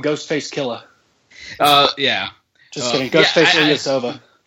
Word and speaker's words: Ilyasova: The guy who to watch out for is Ilyasova. That's how you say his name, right? – Ilyasova.